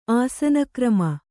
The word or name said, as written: ಆಸನಕ್ರಮ